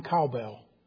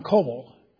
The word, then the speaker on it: Coble: And they said, "Who is this Don Cowbell?" Well, that's the way his name is spelt. But he's Don Coble.